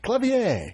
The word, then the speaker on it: Clavier: Clavier. – Yeah. Sorry, you just told me how to say it and I said it wrong. – No, that's okay. Well, yeah. That's the French pronunciation, so hey.